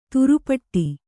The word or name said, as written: ತಿರುಪಾಟ್ಟು